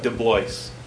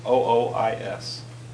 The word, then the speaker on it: DeBlois: DeBlois, and that's spelled DeBlois, and it's Dutch.